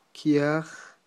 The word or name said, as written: kyaj